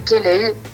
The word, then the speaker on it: Kaleigh: Mon nom, c'est Kelley. Ça s'écrit K-A-L-E-I-G-H.